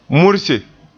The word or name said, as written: murse